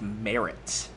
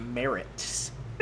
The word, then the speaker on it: Marz: It's spelled M-A-R-Z, and it's pronounced Merritts.